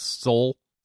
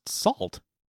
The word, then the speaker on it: Sault: S-A-U-L-T. – Yeah. – That's pronounced salt. It's just, it should be salt. – They pronounced it Sault. – It's the name Saul with a T on the end of it.